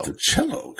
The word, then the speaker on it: Monticello: In some places, it's Monticello. – Monticello, correct. Yeah. – There's even one here in Tennessee. Anyway, and that one's pronounced Monticello, I believe.